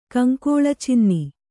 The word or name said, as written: ಕಂಕೋಳಚಿನ್ನಿ